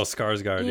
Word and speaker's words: Skarsgård: There's Tarzan, the one with the really fancy Alexander Skarsgård, whose name I can't even pronounce. – Oh, Skarsgård, yeah.